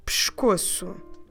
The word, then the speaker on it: pescoço: But when we say it rapidly, we say pescoço, dropping completely that letter E on the first syllable.